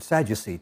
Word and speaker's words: Sadducee: But there was a group who called themselves the uh, Zadik, the righteous ones. They supported his claim. And we pronounce that word Sadducee today.